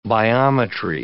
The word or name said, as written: biometry